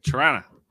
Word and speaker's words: Toronto: You know, did she say it right? – No, you don't. Toronto.